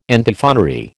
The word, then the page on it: Antiphonary: American English